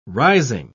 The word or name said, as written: rising